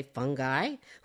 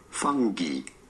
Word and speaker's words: fungi: In British English, it is usually pronounced as fungi. You do want to stress on the first syllable, the fun syllable, fungi. In American English, however, it is usually said as fungi. Fungi in American English versus fungi in British English.